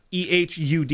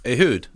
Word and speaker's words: Ehud: The planning of this side mission fell to, I'm going to butcher this guy's name and I'm going to apologize now, Ehud Barak. – He said it's kind of fine. – It's Ehud is his first name. – Ehud. – Yeah, so close enough.